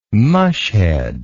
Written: mushhead

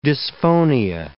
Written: dysphonia

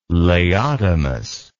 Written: laodamas

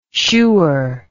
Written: shoer

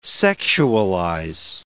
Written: sexualize

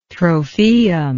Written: trophaeum

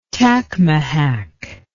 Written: tacmahack

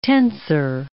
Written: tensor

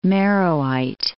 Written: Meroite

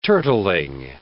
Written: turtling

